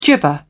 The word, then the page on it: gibber: British English